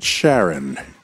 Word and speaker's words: Charon: Well, coming up next, more about Pluto's largest moon, Charon. David, am I pronouncing that right? Charon.